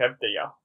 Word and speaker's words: Avdija: I don't know how to pronounce that. – Yeah, Avdija, I think. Avdija. – Avdija. It's probably, yeah.